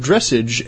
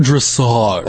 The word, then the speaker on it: dressage: dressage and synchronized swimming now that the Olympics are over. – It's pronounced dressage. – Oh, I'm sorry, dressage.